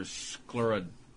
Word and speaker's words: scleroderma: You know, Bob Crane mentioned a few weeks ago the John Candy story about the benefit for scleroderma, if I'm correct pronouncing the disease. – I think you got it right, yeah.